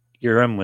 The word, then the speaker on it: Urim: U-R-I-M. – Urim with an L.